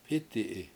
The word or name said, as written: p7Iti7i